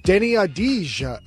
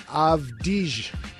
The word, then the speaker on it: Avdija: Deni Avdija uh, I forget, avdige is how you think I uh, you pronounce it in Hebrew, but he becomes the first Israeli born player to go in the top 10.